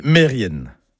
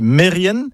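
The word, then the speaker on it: Merien: Et puis, il y a un autre mot, par contre, qui lui n'est jamais bien prononcé, c'est Mérienne. Mérienne, comme ça, ça ne vous parle pas. Mais si je dis Mérien, oui, vous allez reconnaître le nom de famille, Kermérien, le nom de lieu. Eh ben non, tout ça, c'est faux. C'est Mérienne, Kermérienne.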